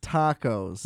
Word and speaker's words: dakos: D-A-K-O-S. – It's pronounced, fucking chump. – It's pronounced tacos. – It's different.